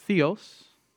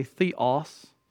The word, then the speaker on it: theos: Theology comes from two words in Greek, theos, or as some Americans say, theos. That's a Texan pronunciation, right, Frank? Theos, or New England pronunciation.